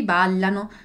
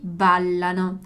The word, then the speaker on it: ballano: Il topo balla, i topi ballano, d'accordo? Quindi, e poi qui la pronuncia della doppia L, ballano, ballano, dillo chiaro? Ballano.